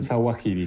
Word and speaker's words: al-Zawahiri: mal al-Zawahiri, yo los pronuncio siempre mal. Este es un gran defecto que tengo, pero eh, lo pronunciaste perfecto, el Sawahiri.